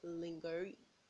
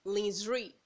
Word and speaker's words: lingerie: L-I-N-G-E-R-I-E. It's not lingerie. It is not lingerie. It is not lingerie. It is lingerie.